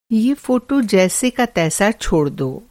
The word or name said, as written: जैसे का तैसा